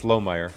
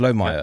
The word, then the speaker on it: Lohmeier: You you did, um, but you put the syllable in a place that I don't normally don't put it. It's Lohmeier.